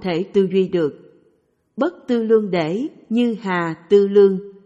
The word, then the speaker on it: tư lượng: Viết là tư lượng, nhưng đọc là tư lương.